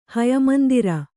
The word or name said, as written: ಹಯಮಂದಿರ